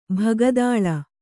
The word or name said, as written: ಬಕ್ಕತಲೆ